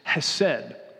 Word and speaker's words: hesed: If you want to hear how to say it properly, ask Pastor Andrew, okay? There's probably some throat to that that I'm not doing, okay? But in common Midwestern English, it's hesed, okay?